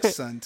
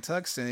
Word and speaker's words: Tucson: Tucson. – Yeah, no, that's exactly how it's spelled. – Right, Tucson. – Tucson.